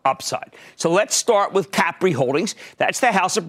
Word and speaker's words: Capri: Capri, which, by the way, that's how they pronounce it over there.